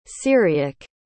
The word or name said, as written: syriac